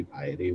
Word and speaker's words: piety: The regular word is pious, so it would be piety with a long I.